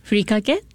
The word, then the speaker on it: furikake: The furikake, is that how you pronounce it? – Yes, furikake, dry seasonings.